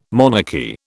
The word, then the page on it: monarchy: British English